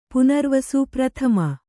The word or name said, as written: ಪ್ರಥಮೆ